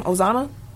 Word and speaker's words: Ozuna: Ozuna, Atlanta Braves.